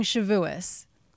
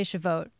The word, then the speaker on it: Shavuos: I mean, depending on the Ashkenazi versus the, you know, Sephardic pronunciation, but he's saying Shavuos. I would probably say Shavuot, but Shavuos is also said.